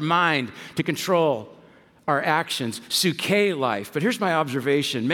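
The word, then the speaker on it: suke (ψυχή): You might say sucky life. But that's not how you pronounce it. Some of you are like, well, that's how my life feels right now. And we want to change that for you. But it's actually pronounced suke.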